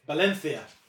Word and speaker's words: Valencia: Would you like to pronounce that properly? – Valencia.